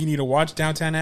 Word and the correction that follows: Downton: I, I, don't even think it's downtown.